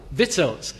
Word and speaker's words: victuals: And in the pulpit, just before I got up to read, he said, how do you say it? Vittles. So there you are, vittles. This is probably victuals, I don't know.